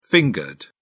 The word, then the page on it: fingered: British English